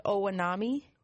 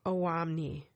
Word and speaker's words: Owamni: But the restaurant's called, is it pronounced Owanami? – Owamni.